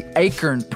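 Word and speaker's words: acorn: Basically, the Akern pronunciation is a throwback to Old English.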